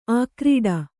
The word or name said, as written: ಆಕ್ರೀಡೆ